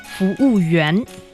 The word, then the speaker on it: 服务员: Okay, bathroom, and then what about the other, maybe 服务人? – 服务员. – Oh, 服务员.